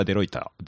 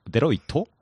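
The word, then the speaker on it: Deloitte: うんえー、で、 6 番手がデロイタ、デロイトデロイトって読むらしいですね。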